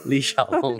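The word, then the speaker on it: Li Xiaolong (李小龍): Li Xiaolong.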